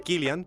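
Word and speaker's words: Cillian: Sí, bueno, yo a mí me emociona la idea de que Killian Murphy, dato curioso, se pronuncia Killian porque es irlandés.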